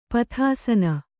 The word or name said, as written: ಪಥಾಸನ